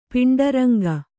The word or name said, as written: ಪಾಂಡುರಂಗ